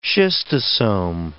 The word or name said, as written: schistosome